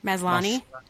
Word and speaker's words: Maslany: Maslany.